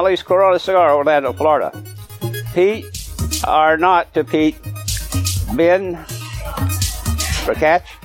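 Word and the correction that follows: BenRiach: R-I-A-C-H. Ben Riach.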